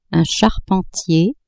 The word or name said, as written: charpentier